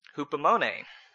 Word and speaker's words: hupomone: Now the other Greek word, hupomone, it's spelled H-U-P-O-M-O-N-E, hupomone.